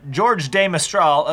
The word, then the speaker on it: George de Mestral: but I'm going to say it's Jorge de Mestral. – I think George. – George. – No, just George. – Just George. – I'm going to pronounce it. – Switzerland is more in the German, French tradition. – George. – Than, George. George of the Jungle. George de Mestral, a Swiss electrical engineer.